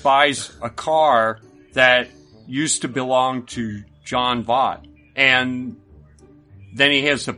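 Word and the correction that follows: Jon Voight: Am I saying his name right? – I don't know. I don't know who you're talking about. – John Voight. – Oh, John Voight.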